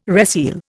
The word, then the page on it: reseal: Indian